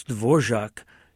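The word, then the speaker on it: Dvořák: It's a agent, and it's Dvorak is his name, because that R has a hachik, as we call it, above it. – Uh-huh. – Has a little V above the R, and it's pronounced zh.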